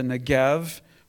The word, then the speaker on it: Negev: In verses 16-18, we push, and Judah continues his success over the desert area of the Negev, pronounced with a V, but sometimes spelled with a B, because it has the same sound, and the wilderness area around the Negev.